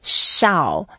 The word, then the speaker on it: Shao: Yeah, it's actually pronounced as Shao, and it's a fourth tone, Shao.